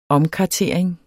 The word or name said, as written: omkartering